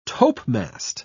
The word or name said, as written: topmast